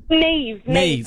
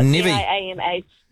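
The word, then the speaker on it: Niamh: Is it Nev or Neve? – Neve. But it's spelled the Irish way, thanks to my father. So Nevy.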